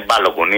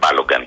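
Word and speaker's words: Balogun: Συνάδελφοι, είναι Μπάλογκουν, είναι παιδί Νιγηριανών μεταναστών. Δεν είναι Μπάλογκαν, να το λέμε σωστά πως προφέρεται.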